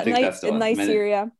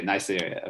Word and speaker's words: Neisseria: And it's mainly caused by three bacteria, Streptococcus pneumoniae, Haemophilus or Haemophilus, I don't know, influenzae, type B, or I can't pronounce this at all. – You do, you got it. – Neisseria meningitis. – Yeah, Neisseria. – Okay, Neisseria. Okay, close enough.